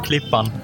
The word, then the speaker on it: Klippan: Klippan.